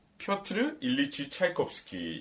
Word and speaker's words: 차이콥스키: 표트르 일리치 차이콥스키 이게 뭐 그래도 러시아 원어 발음인가 봐요.